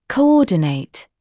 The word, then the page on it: coordinate: kəu’ɔːdɪneɪt; коу’оːдинэйт